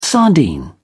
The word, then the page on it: sardine: British English